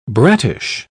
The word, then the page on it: brattish: British English